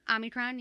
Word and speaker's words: Omicron: Omicron, yeah. – I keep seeing there's no N in the Omni. It's just Omi.